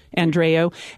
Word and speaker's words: Andreu: Is it Andreu? – Andreu. – And his wife, Betsy. Can you talk about that a little bit? – Yeah, Vanessa, do you want to take that? – Sure. Frankie Andreu